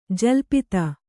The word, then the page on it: ಜಲ್ಪಿತ: jalpita